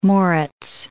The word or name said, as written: Moritz